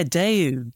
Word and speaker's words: Daewoo: Daewoo, I think you pronounce their name. They did their own console, didn't they? The Zemix console. – That's right, yeah, Daewoo. Daewoo, so Daewoo is one of these. – Daewoo, right, yeah.